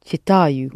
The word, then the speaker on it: читаю: Only accented on «а». – Я читаю.